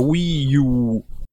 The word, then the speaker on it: Wii U: Di cui, tra parentesi, so anche leggere il nome in giapponese, visto che mi sono messo a studiare anche il giapponese, ed effettivamente si pronuncia Wii U. Perché c'è la U lunga, comunque, vabbè. – You, esatto.